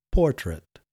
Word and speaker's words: portrait: I think I completely messed up the pronunciation of portrait yesterday because in Dutch we say portret, with so we emphasize the second syllable. And I think I messed it up a bit. So yesterday I should have said portrait.